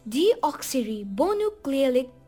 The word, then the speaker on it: deoxyribonucleic: I know that was a little tough for you to pronounce. It is deoxyribonucleic. Can you pronounce it after me? Repeat it, please.